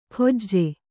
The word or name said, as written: ಖೊಜ್ಜೆ